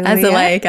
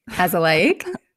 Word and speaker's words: azelaic: Azelaic acid. – Azelaic. Okay. Francis asks, when will we be able to get the, say it again. – Azelaic.